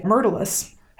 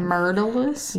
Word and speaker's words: Myrtilus: Myrtilus? – Yeah. Unless I'm saying it right. – Wrong. No. I believe you. It just sounded like murder. And it made me... – It's Myrtle, like creeping Myrtle.